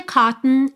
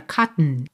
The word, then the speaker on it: cotton: O que é interessante a gente reparar é que esse segundo O não tem muita força. Inclusive, na pronúncia americana, ele é realmente suprimido. A gente vai do T direto pro N num som pra dentro. Cotton. And the cotton is cotton. E fica um som totalmente nasal aqui. Cotton is high. Ok? Cotton is high. Hi, H-I-G-H, soa como hi, oi.